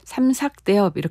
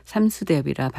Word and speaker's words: 삼수대엽: 삼수대엽 또 삼삭대엽이라고 발음하기도 하죠. 가곡을 하시는 분들은 가곡의 그 발음을 중요하게 생각하기 때문에 삼삭대엽 이렇게 발음 안 하고 그냥 삼수대엽이라고 발음하셔서 저도 삼수대엽이라 발음합니다.